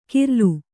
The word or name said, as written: ಕೀರ್ಲು